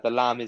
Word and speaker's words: Lam: The Lam is spelled L-A-M. So it's Oliver L-A-M Watson.